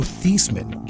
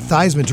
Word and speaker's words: Theismann: My favorite piece of trivia is that Joe Theismann won the Heisman, but up until that point, His name was actually pronounced Joe Theismann. – Joe Theismann? – Yeah, he changed it to Theismann to rhyme with Heisman.